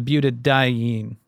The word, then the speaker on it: butadiene: It's the butadiene. Am I saying that correctly? – That's right.